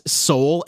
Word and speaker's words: sol: And they give you the Solfege syllables and it is soul, S-O-L, and it is correct. And then in Horizon Forbidden West, they say it as so, which is wrong.